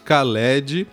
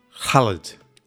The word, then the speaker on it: Khaled: Khaled é como a gente acaba falando aqui no Brasil, né? Lá na Argélia o pessoal pronuncia, aí mais uma vez atenção para minha pronúncia, Khaled. Khaled, alguma coisa assim.